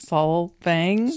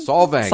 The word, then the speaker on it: Solvang: Solvang. – Solvang. – Solvang. – Accent is on the first syllable.